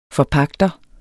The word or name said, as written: forpagter